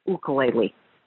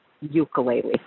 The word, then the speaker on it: ukulele: At the end of the show, it pained me to hear you mispronounce the instrument. It's ukulele, not ukulele. Ukulele.